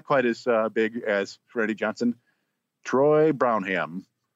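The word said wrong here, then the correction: Brohawn: Or Brohan. I don't know how to pronounce his name. – It's definitely a Brohan.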